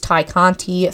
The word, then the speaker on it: Ty Conti: Oh, you were shaking your head no at me, and I literally thought that I said Ty Conti's name wrong. – No, you said it right.